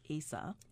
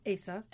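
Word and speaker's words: Eisa: Yeah, it's actually pronounced Asa. – Asa. – And that's the Okinawan style of drumming, which is very distinct from Taiko, which is the Japanese drumming.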